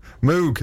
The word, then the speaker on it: Moog: How do you say it then? – Moog. – Oh. Why not M-O-G-E then? That would make more sense. – Because that's his name.